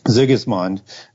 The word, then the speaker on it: Sigismund: Sigismund, I don't think I pronounced it quite the first time there.